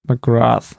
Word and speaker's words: McGrath: Wie spricht man es denn jetzt richtig aus? McGrath? McGrath? – McGrath. Ich glaube, McGrath. Also so, also glaub schon ganz normal TH.